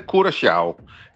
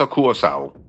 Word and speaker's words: Curaçao: Ja, hvis du må tage det helt korrekt, så siger det, hedder Curaçao. Det der C med den lille krølle under, det får sådan en sjav-lyd, men langt de fleste, de udtaler blot som et S, C, så Curaçao.